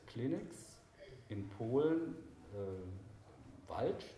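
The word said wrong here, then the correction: Wałcz: ist das richtig ausgesprochen? – Wautsch wird das ausgesprochen.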